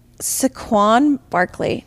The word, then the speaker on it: Saquon Barkley: For my pick, I'm going to choose Saquon Barkley.